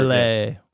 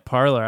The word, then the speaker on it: Parler: Parlay. – Parlay. – Did they give it a French name? – Yeah, that's what it's apparently a French pronunciation.